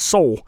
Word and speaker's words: Seoul: I almost called it Seoul, but that's not how you pronounce it. – Seoul. – It's Seoul.